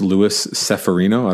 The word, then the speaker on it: Luis Severino: I believe his name is actually pronounced Luis Severino.